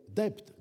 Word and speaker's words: debt: qui vous prononce « debt », dette, alors qu'il devrait dire debt, D-E-B-T, dit-il, et pas D-E-T.